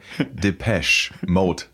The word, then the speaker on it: Depeche Mode: Nachdem wir das, ich muss jetzt aufpassen bei der Aussprache, Depeche Mode Special zu Black Celebration hatten, weil ich glaube, habe da immer ein bisschen ein etwas I-Ton reingebracht.